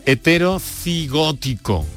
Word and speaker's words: heterocigótico: También incluso estuvo en el primer trasplante renal eh, que, se llevó a cabo heterozoonótico y que, eh, lo he dicho mal, lo he dicho mal, heterocigótico, heterocigótico, o el también primer injerto en bypass aorto coronario.